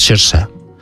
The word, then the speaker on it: Saoirse: que como es gaélico es Saoirse. Es como se pronuncia.